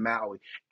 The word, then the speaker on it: Maui: That's how you say it, Maui, Hawaii, the island of Hawaii that went through that wildfire.